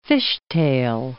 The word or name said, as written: fishtail